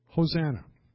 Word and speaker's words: Hosanna: So you put them together, it's either Hoshea na or Yasha na. We pronounce it Hosanna.